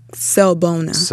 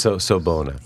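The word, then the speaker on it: Sawubona: How did you say it? – Sobona. – Sobona. – Yes.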